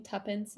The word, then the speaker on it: tuppence: I think Connor's been pronouncing Tuppence, Tuppence? – Tuppence. – That is tuppence. – It's like, it's supposed to be like the British way of saying two pence.